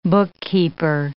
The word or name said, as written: bookkeeper